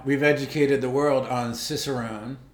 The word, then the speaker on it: Cicerone: It's a Cicerone. – It's a what now? – The beer sommelier is called a Cicerone. – Is it, you sure it's not Chicarone? – It has the pronunciation. Cicerone.